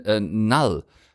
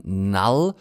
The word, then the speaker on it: Knull: Ja, damit hatte ich im Film tatsächlich ein paar Probleme, denn Null wird dieser furchtbare Typ eigentlich noch ein bisschen schlimmer als Thanos, kriegen wir so eingebläut, ausgesprochen. Knull, also K-N-U-L-L geschrieben, Null, oberböser Alien, gegen den Venom mit Brock zusammen kämpfen muss.